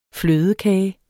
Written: flødekage

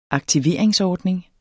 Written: aktiveringsordning

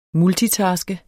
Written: multitaske

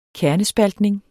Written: kernespaltning

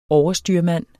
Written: overstyrmand

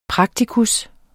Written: PRAKTIKUS

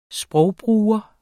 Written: sprogbruger

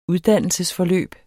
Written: uddannelsesforløb

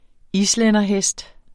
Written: islænderhest

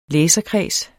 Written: læserkreds

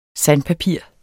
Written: sandpapir

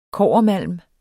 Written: kobbermalm

